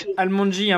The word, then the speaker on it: ALMONDGY: Almondji, hein, pour ceux qui nous écoutent, ça s'écrit A-L-M-O-N-D-G-Y.